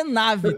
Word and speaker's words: NAVE: Então você já pode botar os três sabores no carrinho, colocar o cupom lá, NAVI, ok? Coloca o cupom. É nave, né? O cupom, né? Tô certo? Falei errado. Nave, nave. Nave, coloca o é cupom.